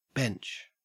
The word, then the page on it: bench: Australian English